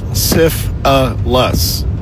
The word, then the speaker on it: syphilis: Syphilis is three syllables. Syph-a-lus.